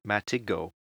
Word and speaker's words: Matigo: I have the same problem when people try to say my pseudonym, which is actually Matigo. So the I is not quite as strong as many people say.